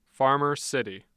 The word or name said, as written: Farmer City